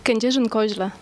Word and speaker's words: Kędzierzyn-Koźle: Kędzierzyn-Koźle ja gut, hätte ich so nicht hinbekommen.